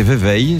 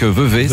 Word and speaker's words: Vevey: Alors, je pensais que ça se prononçait Vevey, je l'ai toujours dit comme ça. Mais ça se prononce donc Vevey, c'est un camarade suisse qui me l'a dit.